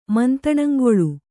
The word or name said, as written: ಮಂತಣಂಗೊಳು